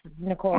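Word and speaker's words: Nichole: Fulmer, F-U-L-M-E-R. – So that's going to be N-I-C-O-L-E, and then Fulmer, okay. F-U-L-M-E-R? – Yes, Nicole, okay. – Spelled with an H. – I'm sorry? – Nicole spelled with an H, N-I-C-H-O-L-E. – Oh, N-A-C-H.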